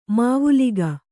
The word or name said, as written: ಮಾವುಲಿಗ